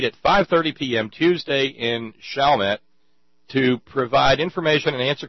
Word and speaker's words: Chalmette: Am I saying that right? Chalmette? – Chalmette.